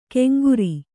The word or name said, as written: ಕೆಂಗುರಿ